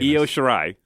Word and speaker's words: Io Shirai: Toni Storm defeating Yoshiari. – What? – Saying that right? The Mae Young Classic. – Io Shirai? – Shirai? Yo, I wonder her name. – Io Shirai.